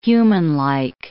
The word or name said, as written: humanlike